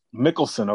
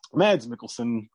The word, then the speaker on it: Mikkelsen: But Khaleesius, played by Mads Mikkelsen, I don't know if I have that pronunciation right. – Mikkelsen, okay. Mads Mikkelsen.